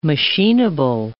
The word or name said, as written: machineable